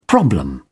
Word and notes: problem: British English

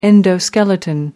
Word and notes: endoskeleton: American English

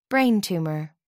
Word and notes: brain tumour: American English

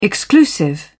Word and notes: exclusive: British English

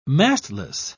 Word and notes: mastless: British English

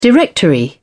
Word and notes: directory: British English

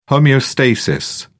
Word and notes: homeostasis: British English

